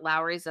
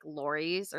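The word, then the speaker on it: Lawry's: Which apparently we've been mispronouncing, and it's not Lowry's at all. It's, like, Lori's or something like that. – No, I don't know.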